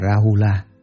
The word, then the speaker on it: Rahula: Ngài La Hầu La, Ấn Độ đọc là Rahula, chữ Hán là Phu Trướng, là thái tử của Phật.